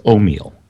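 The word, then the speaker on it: Omille: It is O'Meal. – O'Meal. – O-M as in Mary, I-L-L-E.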